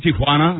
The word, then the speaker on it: Tijuana: Tijuana? – Uh-huh. – That's our story when we return. – Why do you say that like that? – Because that's the correct pronunciation of that word.